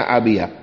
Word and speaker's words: Abía: En forma de acróstico se escriben con la sigla Abía, que se pronuncia Abía.